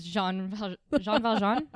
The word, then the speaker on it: Jean Valjean: So he's trying to portray himself as Jean Valjean. Am I saying that right? – Yep. Yeah.